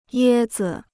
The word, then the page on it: 椰子: yē zi